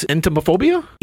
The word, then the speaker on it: entomophobia: I'm sure I'm going to butcher this. Entomophobia? E-N-T-O-M-O-phobia. – Sure.